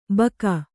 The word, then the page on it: ಬಕ: Kannada